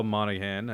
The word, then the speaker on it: Monaghan: We have Michelle Monaghan, I think, if I'm saying that correctly.